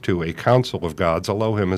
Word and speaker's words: Elohim: In Hebrew pronounced Elohim.